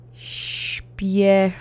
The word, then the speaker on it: Śpieszy: Accent is on the first syllable. Ready? Let's go. Śpieszy.